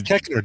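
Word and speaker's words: Koechner: David Kochner. Or Cochner, however you say his name. – Keckner, David Keckner from Anchorman, and Get Smart, all that stuff.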